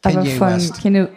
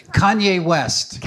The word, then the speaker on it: Kanye West: Kanye West. – I didn't even know how to say his name. Kanye West.